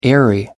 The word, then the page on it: airy: American English